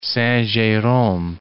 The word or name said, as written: Saint-Jerome